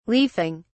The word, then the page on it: leafing: British English